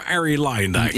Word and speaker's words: Arie Luijendijk: Wordt het die nieuwe Arie Luijendijk? – Ja, zo moet je het eigenlijk uitspreken.